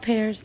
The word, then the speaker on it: Pears: Is it called pears? – Yeah. – That's how you pronounce it, right? – Yeah.